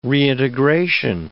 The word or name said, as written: reintegration